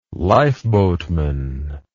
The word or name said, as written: lifeboatman